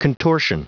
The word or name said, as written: contortion